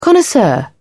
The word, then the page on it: connoisseur: British English